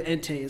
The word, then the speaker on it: Entei: I have Entei. Entei? Is that how you say it? Mm-hmm. I have Entei as my number six.